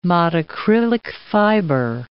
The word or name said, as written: modacrylic fiber